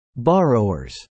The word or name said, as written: borrowers